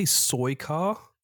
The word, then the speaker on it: Suika: Did you say Suika? – Suika.